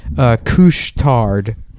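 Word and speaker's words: Couche-Tard: In Montreal, they also have, a place that's called Couche Tard. His name, but Couche is spelled like douche with a C.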